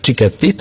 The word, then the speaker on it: Cicchetti: I Pietro Cicchetti, jeśli dobrze wymawiam jego nazwisko. Cicchetti, tak? – Tak.